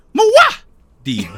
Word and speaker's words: Muad'Dib: Mario is the fucking Muad'Dib. – He rides the Wiggler. – He will join the Toads and lead them to glory. – I'm sorry, Kevin. The pronunciation is Mua Deep.